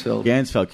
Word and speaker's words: Ganzfeld: Ganzfeld.